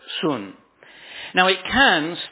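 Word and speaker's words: sun (σύν): It is spelt in English S-U-N and pronounced sun.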